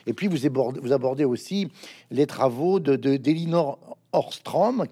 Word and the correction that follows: Ostrom: qui a été je prononce mal, Ostrom,